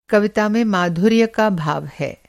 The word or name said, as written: माधुर्य